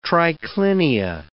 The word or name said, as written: triclinia